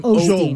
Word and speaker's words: Osteen: Please stop putting an L in his last name. – How do you say his name? – Osteen.